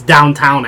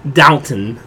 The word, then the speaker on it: Downton: This past week, now you two are going to make fun of me because I don't know how to say this, but I always say it's Downtown Abbey, but that's not how you say it, right? – Downton? – Downton. – Yes. – Downton. – Yes. – Abbey. – There's no W.